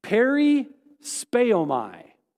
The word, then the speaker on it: perispeomai: The word distracted in Greek is pronounced perispeomai.